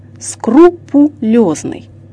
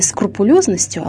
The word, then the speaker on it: скрупулезность: Слово «скрупулезность» созвучно с русским словом «крупа» – «скрупой», то есть «по крупинке», а значит произносится как «скрупулезность», «скрупулезный».